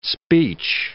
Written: speech

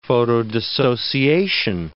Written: photodissociation

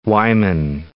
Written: Wieman

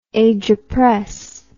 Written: agerpress

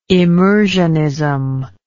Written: immersionism

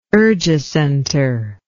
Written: urgicenter

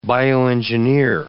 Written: bioengineer

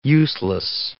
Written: useless

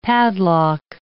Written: padlock

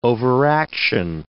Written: overaction

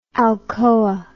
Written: alcoa

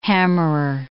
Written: hammerer

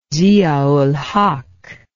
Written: Zia ul-Haq

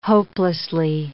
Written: hopelessly

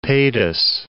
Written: Padus

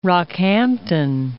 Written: Rockhampton

